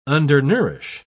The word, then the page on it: undernourish: British English